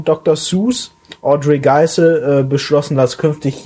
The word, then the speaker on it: Seuss: Tatsächlich spricht man Dr. Seuss gar nicht Dr. Seuss richtig aus, sondern es heißt eigentlich Seuss wie in Voice.